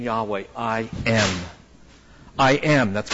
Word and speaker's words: Yahweh: In Hebrew, we believe that name is pronounced Yahweh.